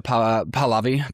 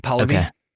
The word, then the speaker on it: Pahlavi: Reza Pahlavi. Pahlavi.